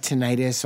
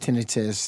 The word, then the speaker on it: tinnitus: So I've had tinnitus, also pronounced tinnitus. There are both ways to pronounce it.